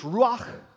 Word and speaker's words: ruach: In Hebrew, it's ruach.